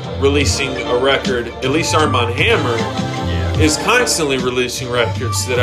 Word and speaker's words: Armand Hammer: Armand Hammer. – Oh, okay, I totally mispronounced that. – Armand Hammer, like, you know, like.